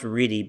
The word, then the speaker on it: Riedie: I have a last name that's spelled R-I-E-D-I-E, and it's pronounced Reedy, but there is no world in which that would be the pronunciation based on that ridiculous, illogical spelling.